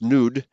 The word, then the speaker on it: nud: So Strong's Concordance gives four related words: nud, NUWD, pronounced nude, and meaning to waver, land of wavering.